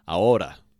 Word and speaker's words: ahora: The word of the day today is ahora. And ahora means now. Ahora, like now, right now. Okay, and that is spelled A-H-O-R-A. Again, H is silent. A-H-O-R-A, ahora.